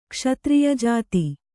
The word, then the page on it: ಕ್ಷತ್ರಿಯಜಾತಿ: Kannada